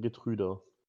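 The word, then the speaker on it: Geertruida: Der wird genauso ausgerufen, Getrüder.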